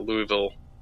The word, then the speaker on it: Louisville: You know, but if you really want to tick them off, just call it Louisville, because that's what it's spelled like. – It is, but do not say it that way.